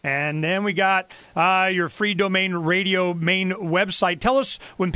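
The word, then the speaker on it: Freedomain: You know, how do you say that? I call it free domain radio. – That's perfect. That's just great, because it's freedom is the main thing, and you want to be free in your own domain.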